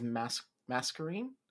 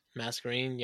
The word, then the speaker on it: Masquerain: The only Pokemon I know that has it is Masquerain.